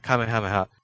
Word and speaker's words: Kamehameha: Yeah, well, the pronunciation of the attack in Dragon Ball kind of lies along Japanese phonology. And so, it also really goes with the Kame part. – Yeah, Kamehameha. And so you kind of have the first syllable, Kame, set up the intonation of the next two. – Right. – Well, the next two parts. And then Ha is kind of, well, it stands on its own anyway, so there you go.